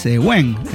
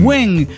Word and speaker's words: Gwen: Gwen Stephanie es Gwen. – Gwen. – Qué nombre raro ese, güey. – Stephanie. – No, Gwen. – Ah, Gwen. – Gwen. – Sí. – Porque se escribe G-W.